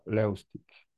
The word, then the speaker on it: Levstik: Hi, my last name would be pronounced Levstik.